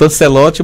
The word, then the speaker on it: Ancelotti: Lancelotti, viu? – Carlos Lancelotti, você sabe quem é Lancelotti? – Ele? – Olha, ele é um italiano. Ancelotti, a pronúncia. É escrito Ancelotti, mas é pronunciado Ancelotti.